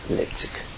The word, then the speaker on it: Leipzig: That's how you say it in Sachsen, Leipzig.